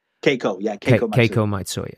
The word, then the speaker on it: Keiko Matsui: and I hope I pronounced this correctly, Keiko Matsui? – Keiko Matsui.